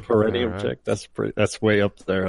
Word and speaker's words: perineum: I think it's perineum.